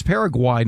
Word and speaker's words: Paraguay: I correctly pronounce it Paraguay. It's Paraguay, not Paraguay.